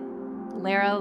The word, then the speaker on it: Lara: You're welcome to refer to me as Lara or as Sharkheart. I always tell people I pronounce it Lara like Sarah, Ruggles like Snuggles.